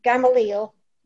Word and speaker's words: Gamaliel: Gamaliel.